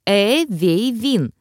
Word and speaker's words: Эвейвин: Эвейвин произносится как Эвейвин с ударением на первый слог.